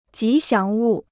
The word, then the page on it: 吉祥物: jí xiáng wù